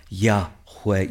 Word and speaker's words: Yahweh: If you were to try and pronounce it, it would sound like this. Jehovah Yahweh. You hear where it comes from?